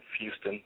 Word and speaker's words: Fuston: And my last name, Fuston, it is spelled F as in Frank, U-S-T-O-N. So just like Houston.